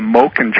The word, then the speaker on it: Moken: there is a tribe down there called the Moken tribe. It's spelled M-O-K-E-N, the Moken tribe.